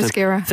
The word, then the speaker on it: Themyscira: The, the tone shift from Thermasira, Skira. Am I saying that wrong every Themyscira. time? – Themyscira. Themyscira.